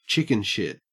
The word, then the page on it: chickenshit: Australian English